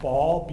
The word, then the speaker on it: Baal: You ever heard of the god Baal, B-A-A-L, Baal is how you say it, right?